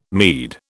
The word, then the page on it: meed: British English